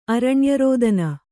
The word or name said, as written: ಅರಣ್ಯರೋದನ